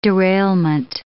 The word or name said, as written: derailment